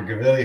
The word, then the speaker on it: Gvili: Gvili. Say it again, Gvili. – Okay. Dr. Gvili, who founded Pelvic NYC.